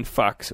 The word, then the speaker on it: Fuchs: So I wrote to Ian at Ian Fox, which I'm not making that up.